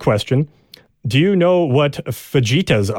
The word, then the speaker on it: fajitas: And I purposely pronounced that wrong. She does have the pronunciation here because I know that we usually pronounce them wrong. Fajitas.